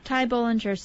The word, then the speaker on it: Ty Bollinger: Ty Bollinger.